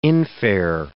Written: infare

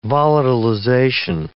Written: volatilization

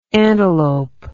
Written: antelopine

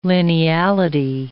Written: lineality

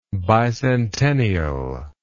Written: bicentennially

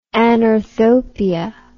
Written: anorthopia

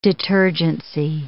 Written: detergency

